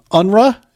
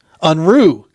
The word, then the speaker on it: Unruh: Unruh, is that right? – It's close. I've been called many things. The correct pronunciation is Unruh. – Unruh. Got it.